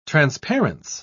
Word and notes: transparence: British English

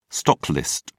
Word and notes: stocklist: British English

